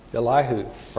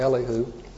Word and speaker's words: Elihu: Elihu, or Elihu, depending on how you pronounce it.